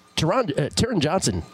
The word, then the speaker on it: Taron Johnson: But he threw it so, it was so underthrown that Taron Johnson, pardon me, that's how you pronounce it.